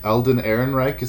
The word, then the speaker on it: Alden Ehrenreich: I think it's Alden Ehrenreich is how you say his name.